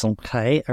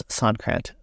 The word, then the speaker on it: Sonkrant: So yeah, my last name is originally French, and I'm not real great at rolling the R's, but I'll do my best. So it's pronounced Sonkrant originally, and then had an E on the end of it. And then it got Americanized, and we dropped the E, and now it's Sonkrant.